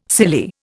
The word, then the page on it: silly: Indian